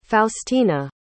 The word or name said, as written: faustina